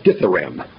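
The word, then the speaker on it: dithyramb: I pronounced that word wrong. It's dithyram, not dithyram. Dithyram.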